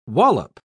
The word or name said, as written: wallop